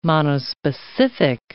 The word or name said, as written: monospecific